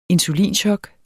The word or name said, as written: insulinchok